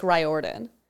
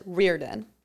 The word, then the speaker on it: Riordan: Well, so he pronounces it as Rick Riordan. – Riordan. – But I had a teacher and he pronounced it Riordan. – Mm. – So I think, yeah, there's different ways.